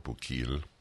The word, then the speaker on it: Bukele: The name of the president of El Salvador, Nayib Bukele, if that is, I don't know how to pronounce it, B-U-K-E-L-E, Nayib Bukele, or Bukele.